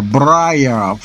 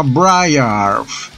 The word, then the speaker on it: Briargh: Eh, la, palabra se pronuncia de la siguiente manera: Briar. – Así que se me dijeron así, porque un true viking me recañó dice que eso no se pronuncia así. o sea, la palabra se llama Briar.